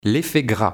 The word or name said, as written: L'effet gras